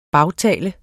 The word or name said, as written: bagtale